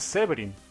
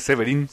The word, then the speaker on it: Severin: La pronunciación inglesa es Severin. – Y acá es Severin. – Acento en la I.